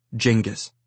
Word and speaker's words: Genghis: Greg makes a point to pronounce the name correctly, with a soft G, Genghis.